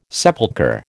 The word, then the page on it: sepulchre: American English